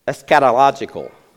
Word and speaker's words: eschatological: In fact, verses 4 through 7 are soteriological and eschatological is how you say it. Eschatological.